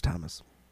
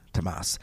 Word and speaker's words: Tammas: It's spelled T-A-M-M-A-S, but it's Thomas. – Okay. – Tomas, a peasant from said Orkney Islands and one of the rare few who survived an encounter with this specific entity.